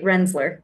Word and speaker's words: Rensselaer: The Rensselaer, how did you say that? – That's right, Rensler.